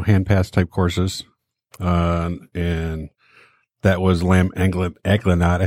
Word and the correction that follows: agnolotti: I can never say that right. – Agnolotti. – Hey, thank you. – Agnolotti.